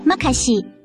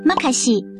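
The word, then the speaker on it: makasih: 印尼语的多谢是马西，读音甲台语马卡真细真共款。马西，越南语的多谢是读感恩。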